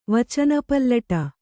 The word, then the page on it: ವಚನಪಲ್ಲಟ: vacana pallaṭa